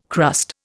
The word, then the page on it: crust: Indian